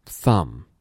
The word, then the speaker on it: thumb: Thumb, thumb. – 稍微注意一下这里的 b 是不发音的。